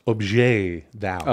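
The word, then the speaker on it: objet d'art: It's pronounced objet d'out.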